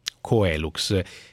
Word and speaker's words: Coelux: che si scrive proprio così e si pronuncia così. Coelux, che ci richiama il cielo, però voi fate illuminazione.